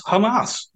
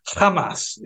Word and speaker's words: Hamas: I mean, UNRWA is Hamas. Well, sorry, I'm not pronouncing it correctly. Hamas, yeah.